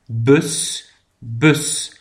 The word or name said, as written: de bus